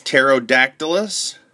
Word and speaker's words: Pterodactylus: Pterodactylus. Say pterodactylus. I know the P is silent.